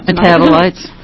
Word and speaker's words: metabolites: As a result, the metabolites, and I think I'm mispronouncing that. – Metabolites. – Metabolites, thank you, can still be present in urine well after the ingestion of marijuana.